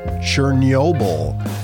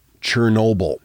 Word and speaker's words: Chernobyl: a number of years ago, I was asked to record the audio for a report about the disaster at Chernobyl, which is how I pronounced it and they kept sending it back to me saying, no, no, you need to pronounce it Chernobyl. Chernobyl is just the way that Americans say it.